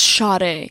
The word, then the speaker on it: Sade: Sade spelt without the H. Just Sade. – It's Sade. – Yeah. – But it's pronounced Sade.